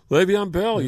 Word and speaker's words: Le'Veon: Le'Veon Bell. Is that how you pronounce it? Le'Veon? – Le'Veon Bell.